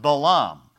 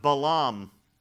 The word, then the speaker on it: Balaam: We typically call him Balaam, but it's pronounced Balaam,